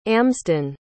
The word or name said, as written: amsden